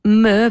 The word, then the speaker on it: Mö: Eh sì, si pronuncia proprio così, Mö, perché è scandinava, adesso non mi ricordo più se è svedese o vabbè, comunque in quella zona d'Europa.